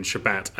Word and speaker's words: Shabbat: What's the difference between Shabbat and Shabbos? I've heard people say both. – Honestly, both ways are accepted ways of pronouncing the Hebrew word, and both refer to the Sabbath, the day of rest between Friday sunset and Saturday sunset.